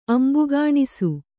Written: ಅಂಬುಗಾಣಿಸು

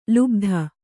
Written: ಲುಬ್ಧ

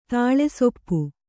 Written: ತಾಳೆಸೊಪ್ಪು

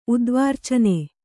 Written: ಉದ್ವಾರ್ಚನೆ

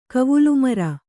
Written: ಕವುಲುಮರ

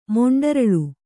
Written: ಮೊಂಡರಳು

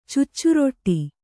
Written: ಚುಚ್ಚುರೊಟ್ಟಿ

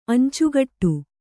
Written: ಅಂಚುಗಟ್ಟು

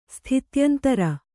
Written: ಸ್ಥಿತ್ಯಂತರ

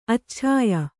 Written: ಅಚ್ಛಾಯ

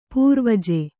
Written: ಪೂರ್ವಜ

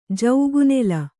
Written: ಜೌಗುನೆಲ

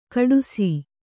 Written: ಖೋಡಾಸು

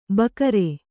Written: ಭಕಾರ

